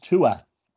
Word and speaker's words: Tuatha: It's pronounced Tua. – Tua. – Tua. Tua. Yeah. – Tua. – That's how it's pronounced.